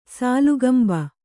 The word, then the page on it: ಸಾಲುಗಂಭ: kannada